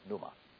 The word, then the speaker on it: pneuma: One, pneuma. Phonetically, it's P-N-Y-O-O-M-A-H, pneuma. And the other one is phantasma.